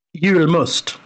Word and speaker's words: Julmust: I'm probably going to butcher this, but Julmust? Did I say that right? – Julmust. – Julmust. – Yes.